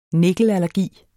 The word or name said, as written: nikkelallergi